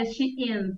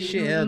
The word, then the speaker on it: Shein: É, cara, é um negócio assim, é de um desconhecimento, porque a, ah, é, eu acho que a pronúncia até é Shein, né? De, Shein, né? Não sei se é, Shein.